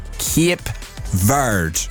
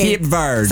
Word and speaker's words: Cape Verde: So they have told me it is pronounced Cape Verde. – Oh, okay. – Cape Verde.